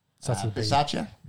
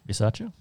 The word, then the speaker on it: Bisaccia: Rich Bassia? Bassia? – Bisaccia? – Bisaccia? – There's a B there.